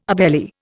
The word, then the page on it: obeli: Indian